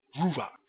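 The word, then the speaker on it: Ruach (רוח): It is pronounced Ruach.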